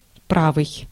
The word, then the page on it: righteous: American English; /ˈraɪtʃəs/